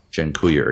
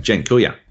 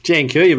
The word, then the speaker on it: Dziękuję: and then they'd make me say Dziękuję again, because they're like, oh, say it again. – It's because you weren't saying it properly, because I was saying it like an Australian. Dziękuję. – Dziękuję, mate.